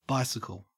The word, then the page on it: bicycle: Australian English